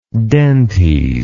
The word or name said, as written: dentes